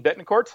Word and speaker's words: Betancourt: Betancourt.